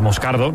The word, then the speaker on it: Moscardo: Entonces, sí, como bien ha pronunciado Unai, es Moscardo,